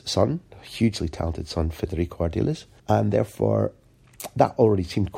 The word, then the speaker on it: Ardiles: would be Ardiles, although we sometimes pronounced it Ardiles at the time.